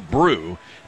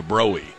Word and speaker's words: Broe: My personal favorite that I came up with was the Brew, B-R-O-E, which is half brew, half shoe. I thought that was pretty good, but I think that people would mispronounce it. They'd call it the Bro or the Broey.